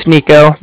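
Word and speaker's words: Niko: All right, like I said, my name's Nico. That's N-I-K-O.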